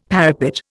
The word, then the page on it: parapet: Indian